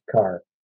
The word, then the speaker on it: Kerr: It's spelled K-E-R-R, but I guess it's pronounced Carr.